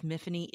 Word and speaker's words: Myfanwy: Now, let's get over the fact that her name is Myfanwy. It's a Welsh name. It rhymes with Tiffany.